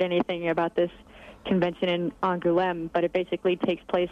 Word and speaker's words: Angoulême: You just went to the Angoulême. Is that how you pronounce it? – Angoulême.